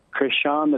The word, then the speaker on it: Crishaun: Good morning, James, and good morning, Crishaun the Don.